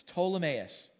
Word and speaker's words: Ptolemais: The P is silent. It's Ptolemais, where we greeted the brothers and sisters and we stayed with them for one day.